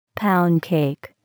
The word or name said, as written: pound cake